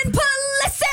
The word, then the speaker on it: Pulisic: Christian Pulisic!